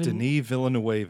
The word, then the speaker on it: Denis Villeneuve: But the director of Dune Part One, Denise. Denise Vin- Denis Villeneuve. – That sounds good.